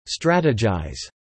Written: strategize

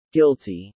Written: guilty

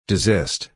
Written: desist

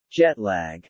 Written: Jetlag

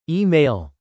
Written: e-mail

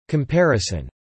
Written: comparison

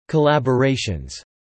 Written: collaborations

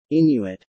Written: Inuit

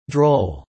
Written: droll

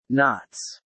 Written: knots

